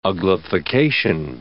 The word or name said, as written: uglification